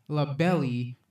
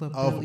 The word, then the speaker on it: LaBelle: It looks like it says LaBelle. – Of our kind. – LaBelle, like, okay? – Well, you know, the E is silent.